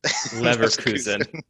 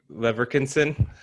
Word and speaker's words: Leverkusen: Leverkusen. – Yeah, exactly. – I would have butchered Leverkusen. – What would you have said? – Leverkinson? – Bayer Lederhosen? – Yeah, one of those.